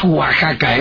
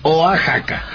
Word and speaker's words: Oaxaca: Oiga, que por cierto, no es Oaxaca, ¿eh? Pronúncienlo bien, es Oaxaca.